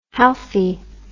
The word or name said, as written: halfy